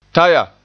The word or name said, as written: taya